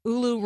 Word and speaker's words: ULU: Oh, ULU rhymes with Hulu.